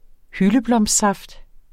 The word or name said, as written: hyldeblomstsaft